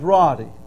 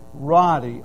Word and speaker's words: Roddey: And I'd see, now my name's Roddy, not Rody, okay, not Rodney. My last name's Roddy, R-O-D-D-E-Y.